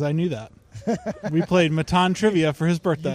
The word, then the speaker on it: Matan: Yeah, Matan, M-A-T-A-N, but it's pronounced like Baton, but M.